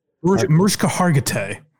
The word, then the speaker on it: Mariska Hargitay: Marissa Haggerty. – Mariska Hargitay.